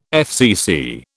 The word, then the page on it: FCC: British English